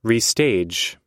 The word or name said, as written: restage